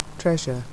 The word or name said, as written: treasure